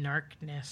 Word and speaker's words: Gnarkness: So the expansion to Goliath is called Summertime Narkness. – Spelled with a G. – G-N-A-R-K-N-E-S-S.